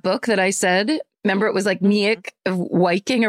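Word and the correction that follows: Meik Wiking: or whatever, his name is Mike Viking. And that's how it's pronounced.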